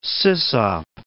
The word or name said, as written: sysop